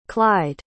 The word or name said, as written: clyde